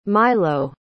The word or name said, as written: Mylo